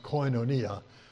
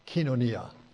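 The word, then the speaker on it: kinonia (κοινωνία): The word you hear from the Greek, you hear it pronounced koinonia. Actually, the Greeks say kinonia.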